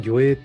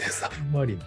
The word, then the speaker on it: 魚影: 最初ね、ずっと黒金の魚影って読んでたんですけど、魚影と書いてサブマリンって読ますですね、これ。